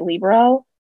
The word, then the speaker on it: libero: So in Italian, because it's an Italian word, in Italian, they like to place emphasis on the first syllable. – Yes. – Pizza, right? Libero. Not libero.